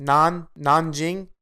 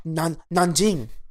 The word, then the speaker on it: Nanjing: Nanjing. N-A-N. – Say it like you're actually from China. – Nanjing.